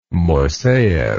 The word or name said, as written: moiseyev